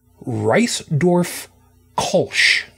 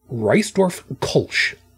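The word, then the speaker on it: Reissdorf Kölsch: And if my German pronunciation is correct, and trust me, I don't expect that it is, I believe it's pronounced Reisdorf Kolsch. Reisdorf Kolsch.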